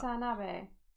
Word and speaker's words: Watanabe: Watanabe. I've completely butchered that. – Watanabe. – I'm usually really good with Japanese pronunciations, but I butchered that.